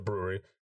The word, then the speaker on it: Bruery: I mean, if you've never had anything from brewery, B-R-U-E-R-Y, we're not spelling it. We're not messing up the name. It's actually pronounced the brewery.